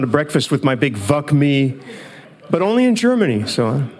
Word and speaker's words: VUC.me: VUC.me. Some people pronounce it VUC me. I don't, because I don't think that's a very nice sound, especially in Germany.